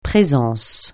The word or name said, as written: présence